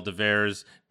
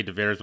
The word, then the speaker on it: Devers: And I never know how to say his last name, where to put the emphasis, but it's Rafael Devers. I always want to say Devers when I look at that name.